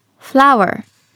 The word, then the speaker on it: flour: まず最初はこちら、花という意味の flower、そして小麦粉という意味の flour。 – 表記は花は FLOWER、小麦粉は FLOUR と違うんですが発音は全く一緒なんですね。